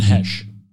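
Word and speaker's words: Heche: Type in how to pronounce Hesh. – Stop giving me long sentences to type. I'm just going to type in her name. – Oh, go to, the Wikipedia has pronunciation keys. – That's what I typed in. – Hesh. Oh. What does it say? – We're going to... Sounds like... yeah, right. You're right.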